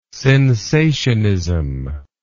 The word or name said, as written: sensationism